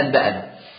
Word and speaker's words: أنبأنا: طيب في بعض الكتب، كتب الحديث زي النسائي، تأتي مثلا كلمة أبنى أبنى أبنى، أيوة دي مقلوبة لكلمة أنبأنا، واضحة؟ تكتب كده أبنى بس تنطق أنبأنا، وضحت كده الرموز؟